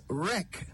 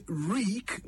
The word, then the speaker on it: wreak: W-R-E-A-K. Reek. Many people pronounce it as wreck, but that is an entirely different word with an entirely different meaning. The word wreck means to destroy or ruin, but the word reek means to inflict punishment or vengeance.